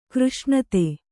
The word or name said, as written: ಕೃಷ್ಣತೆ